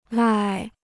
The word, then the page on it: 赖: lài